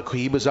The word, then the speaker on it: Cohiba: My decision, you know, if I were just faced with these two cigars, the face and the Cohiba. Did I say that right? – Yep, Cohiba.